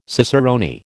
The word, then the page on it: cicerone: American English